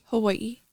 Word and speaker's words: Hawaii: I love the way you say Hawaii. Can you say that again? – Hawaii. – That's the way it's supposed to be said.